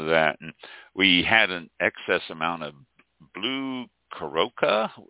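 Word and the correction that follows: Curaçao: is it pronounced? I'm, I can not oh. remember. Uh, blue Curacao, yeah. Yeah, blue Curacao.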